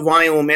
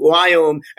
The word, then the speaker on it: Viome: So, the Indians can't pronounce the word we. So we as in Victor, I-O-M-E. So it's called Wyome. And I know you're probably thinking that's not spelled Wyome, but that's okay. That's how it is.